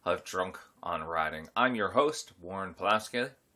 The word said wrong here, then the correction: Pawlowski: Ah, I said my name wrong. I'm your host, Warren Pawlowski.